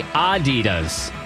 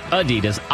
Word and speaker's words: Adidas: And finally, Americans pronounce Adidas wrong. It's supposed to be Adidas, not Adidas, Adidas. The emphasis on the ah at the beginning.